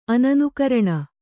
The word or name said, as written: ಅನನುಕರಣ